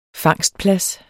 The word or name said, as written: fangstplads